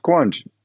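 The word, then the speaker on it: Quandt: How do you say the Quant family? – Quant? Yeah, Quant. Yeah. – Okay. I got it right. I have Quant. – It's like quantitative. Quant.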